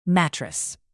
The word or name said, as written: mattress